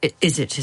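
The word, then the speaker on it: izzat: Izzet is how it's pronounced.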